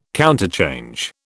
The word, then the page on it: counterchange: British English